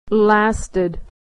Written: lasted

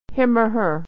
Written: or her

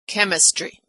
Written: chemistry

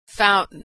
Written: fountain

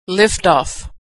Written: liftoff

